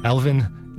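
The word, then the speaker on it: Alwin: It's with a W, but I want to pronounce it with a, as a V. Alvin Nikolais.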